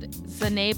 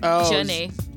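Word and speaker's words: Zhané: It's spelled Zanae, but it's pronounced Janae. – Yeah, Z-H-A-N-E with apostrophe.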